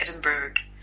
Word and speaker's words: Edinburgh: No, it's American, so it's Berg, like Edinburgh.